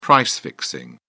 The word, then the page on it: price-fixing: British English